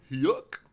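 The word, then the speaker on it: Huyck: yeah, so he was, he co-wrote that movie with his friends Willard, I believe it's Huck or Huke, I actually don't know how to pronounce his last name. – Huck? – Yeah, exactly.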